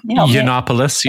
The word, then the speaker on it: Yiannopoulos: Yiannopoulos.